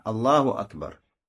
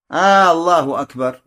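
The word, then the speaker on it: Аллаху Акбар (الله أكبر): Аллаху Акбар. Так правильно. Аллаху Акбар. Если кто-то скажет Аллаху Акбар. Аллаху Акбар. Удлинив первую букву, это неправильно, недействительно.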